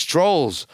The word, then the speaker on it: Troels: There are Danish shipping companies talking to Troels Lund Poulsen and saying, hey, Trund, or no, it's Trolls. It's spelled T-R-O-E-L-S, Trolls.